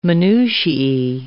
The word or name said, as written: minutiae